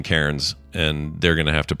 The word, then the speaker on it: Cairns: This is C-A-I-R-N-S, right? – Correct, yes. – That if that is spelled Cairns, then that's effed. – it's pronounced Cairns. Oh, yeah, Cairns.